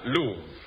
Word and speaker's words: Louvre: Stolen from the, how do you pronounce this? – Louvre. L-O-U-V-R-E, pronounced Louvre.